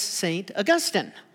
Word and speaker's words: Augustine: The city is St. Augustine. The grass is Augustine. And it's Augustine grass. But when you are speaking of the Bishop of Hippo, that great theologian, it's St. Augustine.